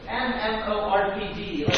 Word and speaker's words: MMORPG: You can have an MMORG game righteousness. Can anybody tell me what I just said? Did I say it wrong? – Yes. – What is it? – MMORPG. – Can you tell me that acronym? – Massively multiplayer online role-playing game.